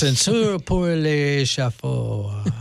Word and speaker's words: Ascenseur pour l'échafaud: Ascenseur pour l'échafaud.